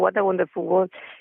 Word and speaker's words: What a Wonderful World: Sí, sí, está bien pronunciado, What a Wonderful World.